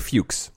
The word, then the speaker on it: Fuchs: How would you pronounce this guy's name? I want to make sure I'm not, like, needlessly... – Mr. Fuchs. – I thought it was Mr. Fux. I mean, right? F-U-C-H-S? – Yeah.